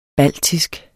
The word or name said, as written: BALTISKE